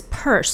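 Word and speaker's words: Peirce: So apparently you pronounce his last name as Purse. So Lincoln Purse is an American cartoonist,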